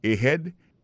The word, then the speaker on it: ahead: Ahead. Ahead, là, c'est A-H-E-A-D. Ahead. Ahead. Go ahead. Là encore, on a point que accent tonique là tombé sous deuxième syllabe.